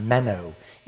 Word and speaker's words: meno: So the same word abide, okay, the little word, it's pronounced meno in the Greek, and it means to draw our life's essence right here.